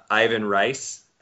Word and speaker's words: Ivan Reis: Well, and of course, everybody's favorite, Ivan Reis, as he was introduced at the panel.